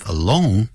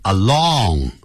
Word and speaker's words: alone: Studies show，诸多的研究啊显示出啊，that people who live alone，啊，记住不要念成 along，那是 A L O N G， along 是沿着，he's walking along the street，他沿着街道走。